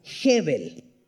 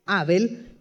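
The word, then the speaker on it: Hebel (הֶבֶל): Y la palabra que utiliza aquí el autor es una palabra que se pronuncia Hebel. Se escribe H-A-B-E-L, Abel, pero en realidad se pronuncia Hebel.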